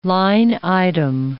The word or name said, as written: line-item